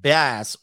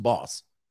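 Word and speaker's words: Bas: What do you think about J. Cole and Bass, or is it Boss? Boss. His name is Boss. Okay, so it's pronounced Boss.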